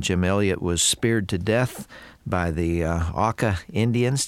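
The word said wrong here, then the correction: Auca: Not quite. The first syllable rhymes with cow. – Ah. – Auka. – Auka.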